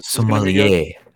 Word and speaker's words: sommelier: I think Sommelier, or however you pronounce this? – Sommelier, sir. Sommelier. It's French.